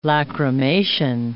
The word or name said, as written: lacrimation